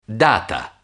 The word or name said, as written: data